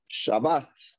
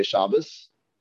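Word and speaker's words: Shabbat (שַׁבָּת): Next word, obviously a famous word, Shabbat. Shabbat. Again. Why do some people say Shabbos? I mean, not why, because it has a saf. But we're pronouncing it Shabbat.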